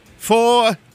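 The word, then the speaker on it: Fore!: Now I'm playing it real straight, and yes, I from 1986 from the Four album, pronounced Four, like they were golfing, but they were just sitting around on the streets, leaning up in their suits on the wall, looking cool.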